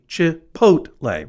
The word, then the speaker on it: Chipotle: So normally it would be Chipotle, right? The P-O-A-T in this phonetic pronunciation should be capitalized, but it's all capitalized as if to reinforce to your dad, this is how you say it, Chipotle.